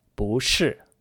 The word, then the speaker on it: 不是: 不 is normally pronounced in that fourth tone, but before another fourth tone word, like 是, it becomes 不 in the second tone. Together, that's 不是, meaning is not, isn't, or it isn't.